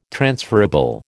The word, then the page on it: transferable: American English